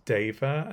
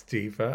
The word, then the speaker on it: Diva: And he said, "I think it's pronounced Deva, actually." And she was like, "No, it's Diva. I know how to pronounce my own name."